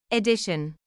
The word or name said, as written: edition